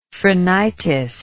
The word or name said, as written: phrenitis